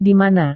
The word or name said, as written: di mana